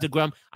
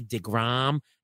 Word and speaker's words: deGrom: I will never buy Jacob DeGrom. By the way, I mispronounced his name on purpose. It's really DeGrom.